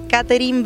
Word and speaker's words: Catherine: Bueno, a través de Catherine Bernes, es mi nombre, se escribe con C, T, H, B corta.